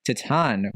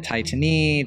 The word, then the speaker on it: Titane: As you all know, our French pronunciation could use a remedial class for sure. We've been calling it Titanie, Titane, it's been ridiculous, but yeah. It's Titan.